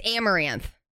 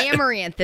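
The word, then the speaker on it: Amouranth: It's Amaranth. – Oh. – What? Amaranth is how you pronounce it.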